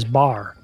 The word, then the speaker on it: bear: As he had done in his poem of July 29, 1858, Dave pronounced bear as bar to create a rhyme.